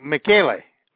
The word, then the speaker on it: Michele: is that Michelle? Is that how you pronounce it? Michele or is that Michelle? – Oh, Michele. Michele. His uncle. Yeah, his uncle. It's Michael. It's really Michael in Italian.